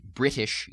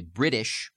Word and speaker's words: British: and that's to substitute a D for a medial T sound. Like, we don't say British, usually, we say British in the States. And this becomes very much a hard feature of the Southern dialect. So I'll pronounce it two ways, first using the medial T, and then going over and substituting that American, and especially American Southern, D substitution.